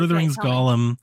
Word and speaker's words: Gollum: is it Gollum? Mm-hmm. I don't do Lord of the Rings. – Gollum.